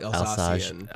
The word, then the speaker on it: Alsatian: And then, how do you pronounce it? Alsatian? – Alsace. Well, the region is Alsace, so it's probably Alsatian.